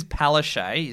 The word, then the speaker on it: Palaszczuk: So this is Palaszczuk is how you say it.